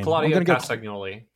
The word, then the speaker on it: Claudio Castagnoli: Claudio Castagnoli.